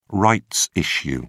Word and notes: rights issue: British English

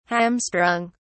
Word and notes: hamstrung: British English

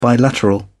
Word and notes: bilateral: British English

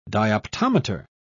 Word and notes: dioptometer: British English

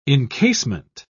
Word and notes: incasement: British English

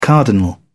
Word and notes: cardinal: British English

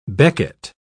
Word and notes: becket: British English

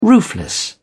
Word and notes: roofless: British English

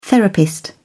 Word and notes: therapist: British English

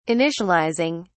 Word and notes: initializing: British English

